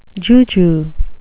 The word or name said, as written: juju